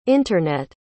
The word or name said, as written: internet